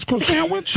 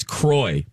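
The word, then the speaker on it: Croissan'wich: Doesn't know how to pronounce croissant-witch. – The croissant-witch. I've called that my entire life. – Says croy, the croy sandwich. – Croissant-witch. – That's her way. That's her special way of saying words.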